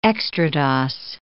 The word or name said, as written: extrados